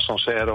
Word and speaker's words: Sancerre: En hoe moet ik het uitspreken? Sancerre? – Sancerre, ja.